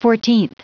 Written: fourteenth

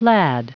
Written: lad

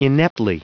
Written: ineptly

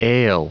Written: ail

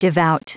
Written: devout

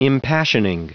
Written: impassioning

